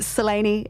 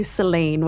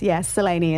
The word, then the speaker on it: Selene: that Selene is how we're saying it, as opposed to Selene, which lots of people tend to go to. but yes, Selene is how we pronounce it.